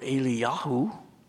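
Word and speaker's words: Eliyahu (אליהו): Until I started pronouncing Elijah in Hebrew, Elia or Eliyahu, it's very close to Eli, Eli, Lama.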